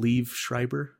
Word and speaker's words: Liev Schreiber: Liev Schreiber.